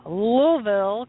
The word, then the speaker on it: Louisville: It is my very, very, very great pleasure to welcome a phenomenal new band from Louisville, Kentucky. That's how you say it, by the way, in case you don't know.